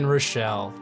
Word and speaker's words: Rachall: Well, I am at Ryan Rochelle, keeping it plain and simple. My last name is spelled R-A-C-H-A-L-L.